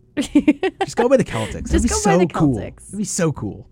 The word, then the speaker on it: Celtics: I think the Celtics would be better. – I think so, too. – Because that's how it's spelled. – It's how it's pronounced. – Like pronounced. You say Celtic music. – Yeah, you wouldn't say Celtic music.